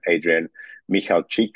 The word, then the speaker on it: Adrian Michalczyk: Like it's basically my name, Adrian Michalczyk is the pronunciation.